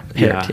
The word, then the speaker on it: Pärt: Yeah, Pärt, yeah. – His name is spelled P-A-R-T, but there's the little double dots above the A.